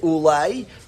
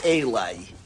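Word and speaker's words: Ulai (אולי): The Ulai is missing a vav. It says Aleph Lamed Yud. We read it Ulai, but it's spelled as if it says Eli.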